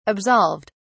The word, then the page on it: absolved: British English